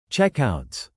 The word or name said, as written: checkouts